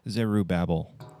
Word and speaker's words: Zerubbabel: Then we have a prophecy concerning, I never know how to say this name, Zerubbabel, I think is what you're... Zerubbabel.